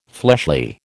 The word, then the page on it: fleshly: American English